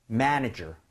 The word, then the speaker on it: manager: 자, 요거 앞쪽에 MA에 강세 주면서 가보도록 할게요. 발음할 때. Alright, 두번 들려드리겠습니다. Manager, manager.